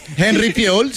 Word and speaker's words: Henry Fiol: Henry Fiol, ¿se dice así? – Henry Fiol, sí.